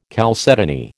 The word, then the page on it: chalcedony: American English